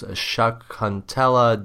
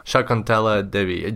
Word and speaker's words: Shakuntala Devi: If anybody's interested to see where how Dublina started out, her name was Shakuntala Devi, right? – Yeah. – Can you pronounce that for us? How do you pronounce it correctly? – Shakuntala. – Shakuntala Devi.